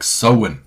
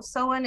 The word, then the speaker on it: Samhain: I know you like giving us the written pronunciation there, but I heard it was more like sewin or something like that. Yeah, I've heard it. So sewin is what I hear from folks who seem to know more than I do about it.